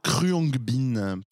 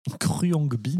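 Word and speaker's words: Khruangbin: Mon coup de cœur est pour un groupe de musique qui s'appelle Kryongbin. – Kryongbin.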